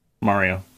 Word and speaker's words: Mario: Mario.